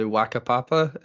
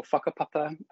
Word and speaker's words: Whakapapa: I believe it's referred to as the Wakapapa. Is that correct? – Yeah. Wakapapa or Whakapapa, depends what part of the country you're from, is how you pronounce that WH.